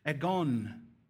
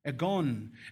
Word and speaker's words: agon: And then for the word race, we have this Greek word, which is pronounced agon. Agon. And this word agon is the root word of our English word for agony or agonize, which should give us a hint about where this is going.